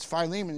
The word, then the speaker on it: Philemon: But he said, that's Philemon, and you better say it right.